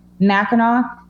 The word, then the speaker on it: Mackinac: So they cut it down to Mackinaw, but they spelled it with an A-C at the end, because in French, that's how you pronounce the A, which looks like, sounds like A-W.